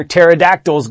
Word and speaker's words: pterodactyls: Why can't you hear pterodactyls go to the bathroom? Because the P is silent.